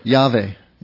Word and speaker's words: Yahweh: And back at Genesis 12, verse 1, you know, the Lord says this to Avram, And I'll give you the names in the Hebrew pronunciation just for fun. At least I'll try to. Now, in fact, Lord, Yahweh. If you see Lord in capital letters, that's Yahweh. Now,